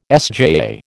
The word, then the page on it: SJAA: American English